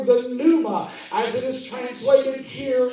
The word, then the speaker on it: pneuma: It kind of spelled like pneumonia, because P-N-E-U-M-A.